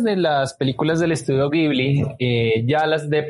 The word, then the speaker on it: Ghibli: En realidad, Ghibli se pronuncia en italiano, que es la palabra de origen. El país de origen se pronuncia Ghibli, pero en Japón, pues la grafía la toman de los sonidos del inglés y para ellos fue Ghibli, Ghibli, para tratar de acoplarlo a la pronunciación japonesa.